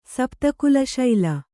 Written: ಸಪ್ತಕುಲಶೈಲ

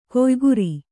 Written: ಕೊಯ್ಗುರಿ

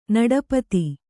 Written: ನಡಪತಿ

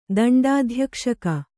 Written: ದಂಡಾಧ್ಯಕ್ಷಕ

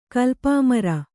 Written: ಕಲ್ಪಾಮರ

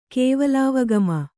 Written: ಕೇವಲಾವಗಮ

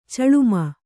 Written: ಚಲುಮೆ

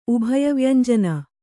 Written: ಉಭಯವ್ಯಂಜನ

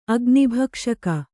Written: ಅಗ್ನಿಭಕ್ಷಕ

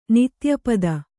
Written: ನಿತ್ಯಪದ

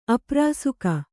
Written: ಅಪ್ರಾಸುಕ

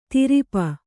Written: ದುರಾಪ

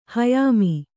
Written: ಹಯಾಮಿ

